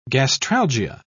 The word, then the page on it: gastralgia: British English